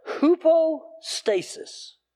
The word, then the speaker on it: hypostasis: Hypostasis. Do you recognize an English word in there? Not hoopo, we're not talking about hippos.